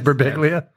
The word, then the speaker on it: Birbiglia: That's how you pronounce it, right? – Birbiglia. – It is Birbiglia.